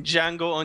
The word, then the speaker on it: Django: And he's back with a silent D in Django Unchained, where he's in the West and he's hunting down bad people.